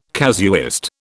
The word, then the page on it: casuist: British English